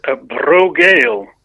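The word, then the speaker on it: Brògeal: And they're called Brogail, is the pronunciation, but it's a B-R accented O and G-E-A-L is how it's spelt.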